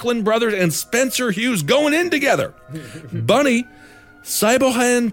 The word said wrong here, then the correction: Siobhan: Thompson, it's S-I-O-B-H-A-N. – Siobhan. That's Siobhan.